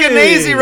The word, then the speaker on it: Skenazy: It's Lenore Skenazy. Rhymes with crazy.